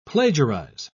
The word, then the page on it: plagiarise: British English